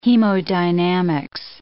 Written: hemodynamics

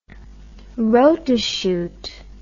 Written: rotachute

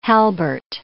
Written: halbert

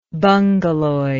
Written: bungaloid

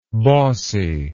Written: bossiness

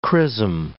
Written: chrisom

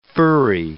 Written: furry

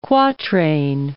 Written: quatrain

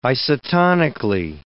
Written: isotonically

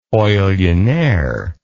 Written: oillionaire

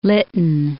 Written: Lytton

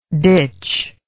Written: ditchless

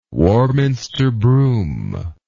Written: Warminster broom

